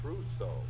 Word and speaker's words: trousseau: I just bought a completely new torso. Hold the wire a minute, sweetheart. – Hey, Remley, how do you pronounce that word? – Trousseau. Correction, darling. I just bought a completely new trousseau.